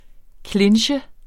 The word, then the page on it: clinche: ˈklenɕə; ˈklendɕə